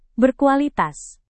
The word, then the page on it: berkualitas: Indonesian